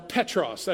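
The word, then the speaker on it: Petros (Πέτρος): "You are Petros." That's how you say it in Greek.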